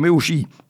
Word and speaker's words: Meucci: Trop tard, il faut dire que si on prononce son nom à l'italienne, puisqu'il y a deux C, ça fait Meucci.